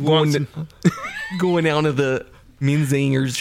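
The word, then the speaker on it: Menzingers: I've always heard it Menzinger's. So not putting the emphasis on the g or j. – No, it's like men and zingers. Menzinger's is how I've always heard it.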